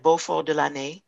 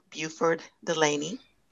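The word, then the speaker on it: Beauford Delaney: It is called Les Amis de Beau, as you said, Beaufort de l'Année. And that's the French pronunciation of the artist's name. In the United States, we would call him Buford Delaney.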